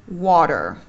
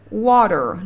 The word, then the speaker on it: water: mình có chữ nước trong tiếng anh anh đọc là water nhưng tiếng anh mỹ sẽ đọc nó là water water lý do bởi vì âm t nó nằm giữa hai nguyên âm là âm o và âm r er, cho nên mình đọc thành là âm d luôn cho nó nhanh